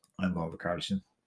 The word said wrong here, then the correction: Richarlison: Richarlison. Okay. I said it wrong with CHs.